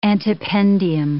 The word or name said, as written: antependium